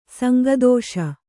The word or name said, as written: ಸಂಗದೋಷ